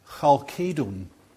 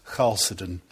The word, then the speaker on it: Chalcedon: So I looked it up, and in Greek it would be pronounced Chalcedon. But I'm not going to use the Greek pronunciation. There are a variety of ways of saying it, so put up with my Scots Chalcedon.